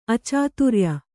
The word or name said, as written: ಅಚಾತುರ್ಯ